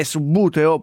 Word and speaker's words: Subbuteo: No, io vorrei chiarire una cosa, perché quando io ero piccolino c'erano persone che lo chiamavano subbuteo. È vero, Leonardo? – Sì, è vero, l'avevo sentito anch'io. – La pronuncia ufficiale è subbuteo, possiamo dirlo.